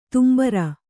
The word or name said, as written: ತುಂಬುರ